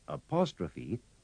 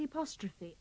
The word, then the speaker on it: apostrophe: In these sentences and dialogues, remember to pronounce the E at the end of apostrophe with the sound of the EY in key. – When do I have to put the apostrophe after the S? – Remember, the dash is longer than the hyphen.